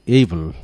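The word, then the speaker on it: able: প্রথম যে শব্দটি, সে শব্দটি হলো এইবল। শব্দটা কিন্তু অনেকে অ্যাবেল বলে, অ্যাবেল না, উচ্চারণ হলো এইবল, A B L E, এইবল।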